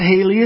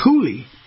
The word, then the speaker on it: Haley: It's not Haley, it's Haley.